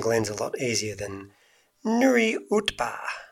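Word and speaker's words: Nuriootpa: So previously known as the Barossa Bush Gardens Parkrun is now going to be called Nuriutpa. – No, it's not. – Yeah, it is. – No, how is it? Go on, try again. – Nuriutpa. – Really? – That's how you pronounce it.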